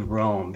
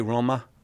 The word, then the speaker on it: Roma: I say Roma. That's how they pronounce it. But whatever, if you want to pronounce it like an American, that's fine.